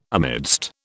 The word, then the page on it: amidst: British English